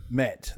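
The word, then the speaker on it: mete: Met. That's how you say it. Met. It's a verb.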